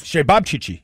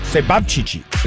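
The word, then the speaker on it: ćevapčići: I'm saying it the way that the person that made it told me to pronounce it. – With the emphasis? – Shebabchichi. Okay. Yes, it's C-E-V-A-P-C-I-C-I. And it's sort of a Serbian or a Croatian sausage.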